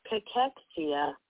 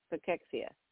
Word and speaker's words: cachexia: Cachexia. – Cachexia. Cachexia. Yeah, cachexia. All right, there it is. That's what they say, cachexia.